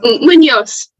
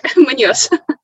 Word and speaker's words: Muñoz: Am I saying that right, Theresa? – Muñoz. – Muñoz. – It's there. Muñoz.